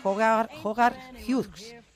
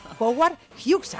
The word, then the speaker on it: Howard Hughes: Su descubridor en el mundo del cine, Howard Hughes. Qué mal me ha salido a mí este nombre, por Dios. Howard Hughes, ahora lo he dicho bien.